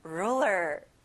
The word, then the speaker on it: ruler: 여기도 앞에 rule를 크게 말해보는 거예요. Ruler.